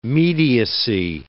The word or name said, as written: mediacy